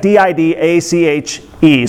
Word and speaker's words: Didache: called the, the Didache, or the Didache, or the uh, Didache. Didac, what it looks like D I D A C H E, so however you think you should pronounce that, Didache is how I say it.